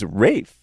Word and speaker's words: Fiennes: Looks like Ralph, but it's actually pronounced Ralph. – I think it's Rafe, right? Rafe Fiennes or Ray Fiennes? – It's Ralph Fiennes.